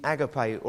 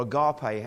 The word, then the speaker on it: agape: agape or agape, however you want to pronounce it, A-G-A-P-E, Greek word.